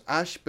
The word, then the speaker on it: Ashe: Si es Ash, pero con E al final, como se pronuncia. – Igual.